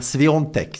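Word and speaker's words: Świątek: Hon får möta Sabalenka, som är världsetta, eller Iga Sviontek, som det uttalas, fast det inte stavas riktigt så, som spelar semifinal typ när som helst.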